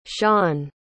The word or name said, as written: sian